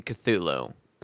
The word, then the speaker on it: Cthulhu: There's only two syllables in Cthulhu.